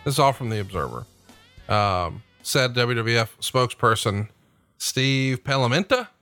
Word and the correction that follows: Planamenta: I said that right? Planamenta? – Planamenta, yeah.